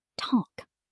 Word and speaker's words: Tag: If you say Tag with a hard G at the end, it sounds unnatural to a German speaker. Make it Tag.